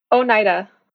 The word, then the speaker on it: Oneida: Was it a one, Oneida? – Oneida.